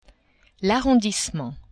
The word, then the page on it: arrondissement: French